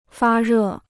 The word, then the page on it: 发热: fā rè